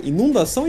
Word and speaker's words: inundação: Imagina um tsunami, imagina uma inundação, imundação Como é que fala? Inundação ou imundação? – É inundação. Eu também.